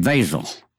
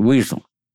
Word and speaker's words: Wesel: It was the Wessel Canal, W-E-S-E-L. They pronounced it Vasel. We pronounced it Weasel.